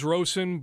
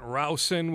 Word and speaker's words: Rowson: I thought it was Rosen, but Levine went Rousen. We'll go James Rousen.